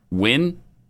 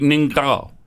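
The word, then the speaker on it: Ngo: Now, when you see an Asian person's name and it's N-G-O and you're an English speaker, mm-hmm. how are you supposed to know that that's pronounced Win? – Yeah.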